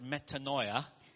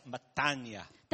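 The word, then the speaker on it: metania (μετάνοια): Repentance in the Greek is, well, the Bible student will pronounce as metanoia. – 所以悔改这个词在圣经原文叫 metanoia。 – But my Greek teacher tells me, that's not how you pronounce it. – 那我的圣经老师，我的那个语言老师会告诉我说，这个不是你发音的正确方法。 – Metanoia is how the Bible student would pronounce it. – Metanoia 只是那些圣经学院的学生们发音的方法。 – But the Greek people pronounce it as metania.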